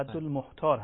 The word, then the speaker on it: رد المختار: من مختار گفتم، بله، رد المختار هست ظاهرا، این چیزی که در تصویر هست. – رد المختار، بله بله، رد المختار، بله، من اشتباه تلفظ کردم.